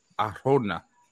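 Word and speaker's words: Arjona: I can't even say his name. – Arona. – Oh, there we go.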